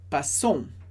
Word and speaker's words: passons: Então, aqui a gente tem o verbo passer, que significa passar, passer, passar, nous passons. Atenção, a gente pronuncia o O-N, mas não pronuncia o S.